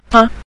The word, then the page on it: ta: ta5